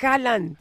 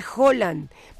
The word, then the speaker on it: Haaland: Oye, y que por cierto, estaba viendo en un video que de un noruego que dice que no se dice Haaland, se dice Haaland, porque en Noruega la doble A se pronuncia como O, pero todo el mundo le dice Haaland, pero lo correcto es Haaland.